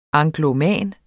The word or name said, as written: angloman